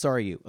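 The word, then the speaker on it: Sarayu: Isn't it Saria? – I've always pronounced it Saryu. – Saryu Blue? – Yeah. – Okay. So it's Saryu.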